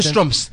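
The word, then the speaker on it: Schtroumpf: They're called the Strumps.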